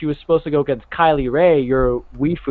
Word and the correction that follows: waifu: or whatever the hell you pronounce it as. – Waifu.